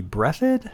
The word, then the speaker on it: Breathed: The artist and author of the strip's name is Berkeley Breathed. – Yeah, I've always pronounced it Breathed. Yes.